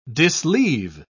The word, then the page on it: disleave: British English